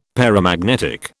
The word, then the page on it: paramagnetic: British English